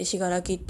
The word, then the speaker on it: 紫香楽: あの、しがらきの字が、あの、信じるに楽しいって言ってたんですけど、あの、実はもう一個書き方があって、紫に薫に楽しいって書いて、しがらきって読ませる場合もあって、